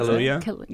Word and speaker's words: Kaluuya: Kaluuya? – Yes.